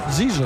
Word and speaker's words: Zizo: Hem de dir Zizo, has dit? – Sí, Zizo. – Zizo, eh? No Zico, eh? – No, Zizo.